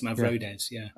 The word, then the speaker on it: Mavrodes: Yeah, that's, and it's similar to like George Mavrodes, I think that's how you say it. – I always call it Mavrodes, but I think you're right. It's Mavrodes.